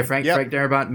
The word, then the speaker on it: Darabont: Isn't this, that's also Frank, is it Darabont? Is that how you pronounce it? – Yeah, Frank Darabont made The Green Mile, he made The Mist, he made The Shawshank Redemption.